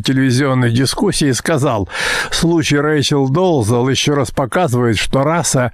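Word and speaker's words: Dolezal: Зовут ее Рэйчел Долзл. О том, что она белая, вдруг заявили ее родители. Кстати, имя, которое американцы произносят здесь как Долзл, это, несомненно, чешское Долежал.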